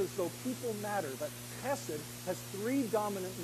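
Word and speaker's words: chesed (חסד): It is pronounced something like this, chesed.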